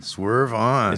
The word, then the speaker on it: Swerve On: Swerve On. – Is the accent is on the on? – Swerve well, the on.